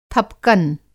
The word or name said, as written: थपकन